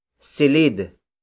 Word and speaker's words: silid: It is derived from the Spanish word cuarto, spelled as C-U-A-R-T-O, which also means room. You can also use the native Tagalog variation, silid. Silid. Silid, which is spelled as S-I-L-I-D.